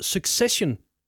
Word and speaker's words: Succession: Det er nemlig serien, der hedder Succession, så vidt jeg ved.